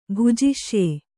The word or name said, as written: ಭುಜಿಷ್ಯೆ